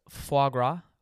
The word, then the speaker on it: foie gras: Foie gras? Yeah, I think it's pronounced foie gras. F-O-I-E-G-R-A-S.